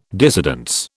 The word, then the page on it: dissidence: British English